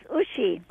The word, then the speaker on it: Ushi: Not quite. It's Deutsch. It's German. – Oh, yeah. – It's Ushi. – Ushi. Yeah, okay. – Yeah, because it's short for Ursula.